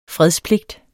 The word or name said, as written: fredspligt